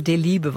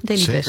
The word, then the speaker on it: Delibes: Ze kregen echt een half A4'tje sarcasme dat ik niet wist wie Leo Delibes was. Want zo moet je natuurlijk uitspreken. Sindsdien weet ik. Maar nu is het toch goed, Delibes.